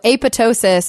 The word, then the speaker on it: apoptosis: Yeah, so apoptosis is the, you know, P-O-E, kind of thinking of it that way, is technically the pronunciation that was intended by the authors.